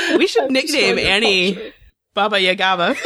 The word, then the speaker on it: Baba Yaga: But Kenzie being on Bo's side had consequences when she did the whole Baba Yaga thing, or whatever. I know I'm saying it wrong. But Baba Yaga thing. I know I added an extra syllable in there.